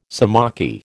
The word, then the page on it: sciamachy: American English